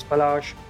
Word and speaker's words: Palash: My name is Palash.